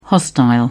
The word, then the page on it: hostile: British English